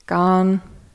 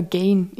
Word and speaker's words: Gane: Entweder Gane oder Gain. Gain war immer die erste Version, bis ich dann, also bei den ersten Kämpfen. Dann glaube ich Gane, aber man sagt einfach nur Garn.